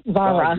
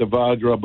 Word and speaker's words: Vajra: And the one that we've had recently, and I believe I was pronouncing this wrong because I was thinking Spanish and I was trying to make an H out of that J, but it's actually Vajra, not Vara. – Vajra.